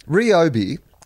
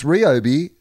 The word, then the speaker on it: Ryobi: Ryobi is how it's pronounced. Mike has put that up on the little message window. And it says, it's Ryobi, source, I can read Japanese.